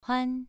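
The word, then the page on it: huan: tone 2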